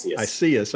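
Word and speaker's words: Isaias: Iseus. – Okay. Yeah. It's pronounced differently than it's spelled.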